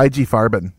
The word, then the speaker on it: IG Farben: Yeah, IG Farben, yeah, was what they, you know, and that's Frankfurt, Germany.